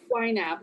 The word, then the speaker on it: YNAB: YNAB, YNAB, but it's right.